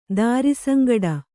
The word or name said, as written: ದಾರಿಸಂಗಡ